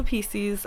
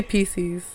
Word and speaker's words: Pisces: Explain to them why you say Pisces. – Oh, yes. I know that it's Pisces, but in Spanish, it's Pisces. And so I just always say it like that.